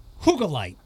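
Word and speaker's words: Hyggelight: They opened a place called H-Y-G-G-E-L-I-G-H-T. I had to, it's not pronounced like that. It's pronounced Hoogalite.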